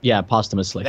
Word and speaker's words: posthumously: I don't think it's the right word, but posthumously. – Yeah, that's it. Yeah. – Yeah. You had the right word, just the pronunciation was a little off.